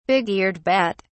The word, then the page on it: big-eared bat: British English